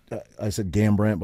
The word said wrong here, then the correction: Garbrandt: but how do you pronounce his last name? – Garbrandt. – Garbrandt, yeah.